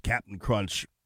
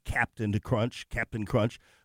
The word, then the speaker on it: Cap'n Crunch: I love the fact that he pronounced Cap'n Crunch correctly, you know, because normally you and me just say Captain Crunch. But he's like Cap'n Crunch.